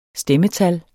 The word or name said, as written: STEMMETAL